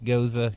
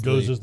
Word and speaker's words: Gose: Spelled G-O-S-E, by the way. – Yes, G-O-S-E, pronounced goza. You will hear all kinds of pronunciations for it. Goza is... – Goza's the right one.